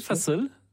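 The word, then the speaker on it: fasıl: Vous proposer d'écouter un fa-sol. – Oui, fa-sol. Ça veut dire concert en turc, si on le dit comme il faut.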